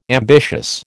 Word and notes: ambitious: American English

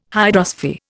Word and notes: hydrosphere: Indian

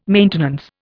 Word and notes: maintenance: Indian

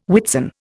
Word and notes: Whitsun: Indian

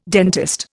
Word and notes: dentist: Indian

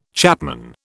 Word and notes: chapman: British English

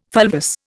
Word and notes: fulvous: Indian

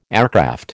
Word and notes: aircraft: American English

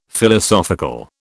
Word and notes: Philosophical: British English

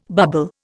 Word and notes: bubble: Indian

